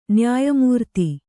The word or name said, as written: ನ್ಯಾಯಮೂರ್ತಿ